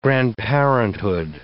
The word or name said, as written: grandparenthood